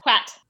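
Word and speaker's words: Hwæt: Quat.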